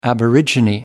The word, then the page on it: aborigine: American English